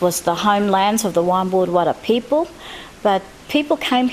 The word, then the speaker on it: Waymbuurr: Wimbuud, it's spelled W-A-Y-M-B-U-U-R-R, Wimbuud.